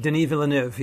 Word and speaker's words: Denis Villeneuve: It's a 2016 sci-fi film by, I'm sorry if I'm pronouncing his name wrong, Denis Villeneuve. Is that the right pronunciation? – Denis Villeneuve, yeah.